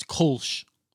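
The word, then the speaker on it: Kölsch: Converter Kolsch? No, it's Kolsch.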